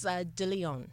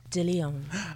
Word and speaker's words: De Lyon: I don't know if I butchered the last name. – A little bit. I was like, eh. But it's De Lyon. – Okay. Yes. And it's Anastasia De Lyon.